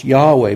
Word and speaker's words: Yahweh: And we take the letters from I am and we get YHWH in English and we pronounce Yahweh.